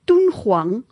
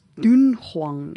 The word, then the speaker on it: Dunhuang: so 'n pleknaam wat gespel word D U N H U A N G moet uitgespreek word as Tunghuang en nie as Dunghuang nie.